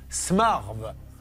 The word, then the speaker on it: Smarve: Que peut-on dire sur Valérie Qu'elle habite à Smarve. Ça se prononce comme ça, c'est à côté de quoi Poitiers.